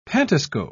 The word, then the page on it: pantoscope: British English